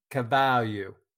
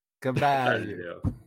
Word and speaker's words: Carvalho: Anyway, Fabio Carvalho. Is that how you say it? Fabio Carvalho? Yeah. – Yeah. – When I looked it up, they pronounce it Cavalio. Cavalio.